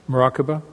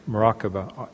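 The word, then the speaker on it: muraqabah: Maraqabah? – Maraqabah. – Okay. – Yes, thank you. – Let's spell it this way. M-U-R-A-Q-A-B.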